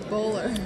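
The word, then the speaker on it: Bolar: We have Kelsey Harkness, and we have the man, I know, Kelsey Poehler, because she got married. – Bowler. – Kelsey Bowler rhymes with Kohler, who is coming on as well.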